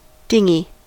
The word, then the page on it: dinghy: American English